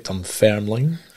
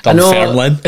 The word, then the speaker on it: Dunfermline: Back at Dunfermline. Mm. Can't even say that right. Dunfermline.